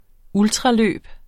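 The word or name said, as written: ultraløb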